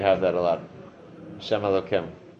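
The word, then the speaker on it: Elokim (אלוקים): If it's Elokim, if it's that Nikud under Yod Kei Vav Kei, then it's pronounced Elokim.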